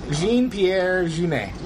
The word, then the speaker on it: Jean-Pierre Jeunet: The director's name is Pierre Genet Jeannot. Hold on. I mispronounced that. – Pierre Genet Jeannot. – Did I say that wrong? Jean-Pierre Jeunet.